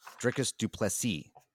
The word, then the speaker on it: Dricus du Plessis: Next fight, Darren Till versus Dricus du Plessis, right? I'm saying that right. – Excellent.